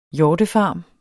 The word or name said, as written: hjortefarm